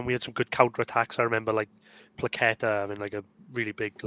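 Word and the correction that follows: Paquetá: Is that how you say his name? – Yeah, I am going to go with that, Paqueta.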